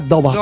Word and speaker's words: Daubach: Pero, míralo aquí, mira, se escribe Daubach, pero suena Dobach, porque es alemán.